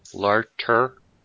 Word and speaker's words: Larter: Ali Lardner. Larter, and Sean Roberts, who I can't believe keeps showing up as Wesker.